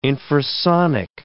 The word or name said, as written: infrasonic